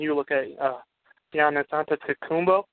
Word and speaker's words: Giannis Antetokounmpo: I've said it right all day practicing because I knew I was going to bring up his name. And I screwed it up there. Giannis Antetokounmpo. There we go.